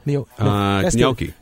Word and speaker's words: gnocchi: How do you say it? Gnocchi? – Gnocchi. Gnocchi, yeah. I think, yeah.